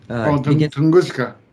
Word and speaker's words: Tunguska: And you, on the Shungite show, asked me if something about, what's the name, the Russian explosion thing. – Tunguska. – Tuluska. – Tunguska. – How do you spell it? – It's Tunguska. Tunguska.